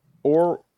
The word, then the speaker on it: aural: A-U-R-A-L. – Aura energy. – Yeah, it's like aura, but with an L.